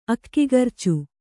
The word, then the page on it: ಅಕ್ಕಿಗರ್ಚು: Kannada